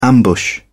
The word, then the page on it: ambush: British English